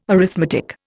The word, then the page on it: arithmetic: Indian